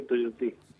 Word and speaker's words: Tuyutí: Número 13, Tujutí, así se escribe. Discúlpame mi guaraní del interior. – 13 Tuyutí.